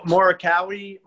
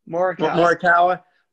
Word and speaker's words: Morikawa: Colin Morikawa. – Morikawa.